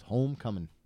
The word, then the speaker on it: Homecoming: Well pronounced. No G on the end. – I know. – It's Homecoming.